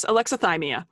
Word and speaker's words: alexithymia: Alexithymia. – Very close. Alexithymia.